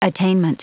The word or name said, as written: attainment